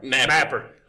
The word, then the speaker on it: Napper: Yeah, because now, Napper, he's taking the I out of it. That's a silent I. – The I's gone. – Yeah. – N-A-P-P-E-R. – Napper. I'm Napper. Billy Napper.